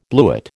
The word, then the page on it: bluet: American English